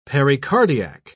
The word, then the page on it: pericardiac: British English